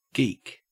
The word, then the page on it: geek: Australian English